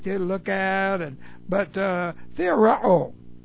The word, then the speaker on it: theoreo: It's the present active indicative of theoreo. And so let's get this word on the board. T-H-E, long O, R-E, long O.